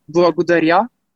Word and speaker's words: благодаря: And in Bulgarian, the proper way of saying it is благодаря.